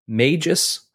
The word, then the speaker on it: Magus: So, the book is titled Exile Magus. I think that's how you say it. M-A-G-U-S. Magus? – Magus? – Magus. Magus? Magus. – I think so.